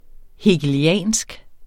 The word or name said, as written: hegeliansk